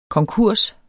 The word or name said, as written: konkurs